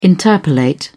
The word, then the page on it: interpolate: British English